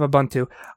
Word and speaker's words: Ubuntu: Speaking of Ubuntu, I know how to pronounce it.